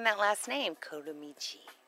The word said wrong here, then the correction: Comaneci: Which is, you didn't say it right. That's why you like saying it so much, because you said it wrong. – Say it for me, encyclopedia. – Comaneci. – Comaneci. – There you go.